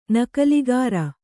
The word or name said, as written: ನಕಲಿಗಾರ